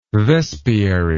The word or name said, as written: vespiary